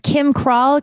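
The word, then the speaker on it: Kym Kral: My name is Kim Krall, KYMKRAL.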